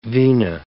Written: vena

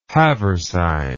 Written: haversine

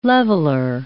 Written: leveller

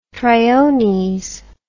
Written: triones

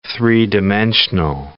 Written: three-dimensional

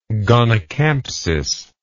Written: gonycampsis

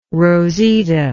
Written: rosita

